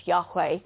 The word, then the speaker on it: Yahweh: In the Old Testament, if you were a Jew and you came across the four letters of the name of God, the YHWH, which is sometimes pronounced as Yahweh, Jews would never pronounce it.